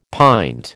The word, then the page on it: pint: American English